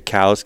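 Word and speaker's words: Coues: When you look at mule deer and coos deer, and obviously you say cows because you're a biologist and that's the correct pronunciation. – I'm a proud member of the 5% that can pronounce it correctly.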